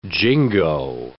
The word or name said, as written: jingo